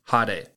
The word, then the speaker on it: hade: Today's Lessons is going to be the Japanese word for flashy, and that is hade. Hade is spelt H A D E.